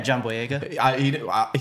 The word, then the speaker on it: Boyega: Yeah, John Boyega.